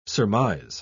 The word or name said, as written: surmise